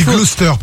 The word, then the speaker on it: Gloucester: Gloucester. – Ah, on dit Gloucester pour info. Ça s'écrit Gloucester, mais c'est, non, c'est pour ton info.